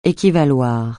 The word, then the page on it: équivaloir: français